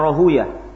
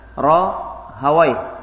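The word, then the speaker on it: Rahawayh: Kebanyakan membacanya Rahuya. Kalau ahlul hadis bacanya dengan Rahawai. Si bawai. Rahawai.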